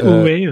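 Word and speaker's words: Ori: Ouré, je sais plus comment ça se prononce. Ouré. – C'est Ouré, ouais.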